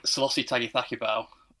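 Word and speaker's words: Sailosi Tagicakibau: I think Selassie Tagithakibau. I probably pronounced that slightly wrong. But no, you've done it right.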